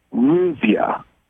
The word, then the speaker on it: Ruzya: Her name is Ruzia. And I believe, if I have this right, she is in Citrus Heights, California. – Would you say her name again? – Ruzia. R-U-Z, let me see, I'll spell it, R-U-Z-Y-A, okay? – Thank you.